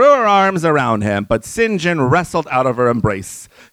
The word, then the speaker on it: St. John: Is that how you pronounce it? Dude, I've been calling you like San Juan for the last 30 pages. No, dear girl, it's Sin Jin, like the sins I don't commit, you know, and the gin I don't drink.